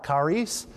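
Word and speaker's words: Charis (χάρις): It's the C-H-A-R, and we would pronounce that car. Charis, kara, and Eucharistia.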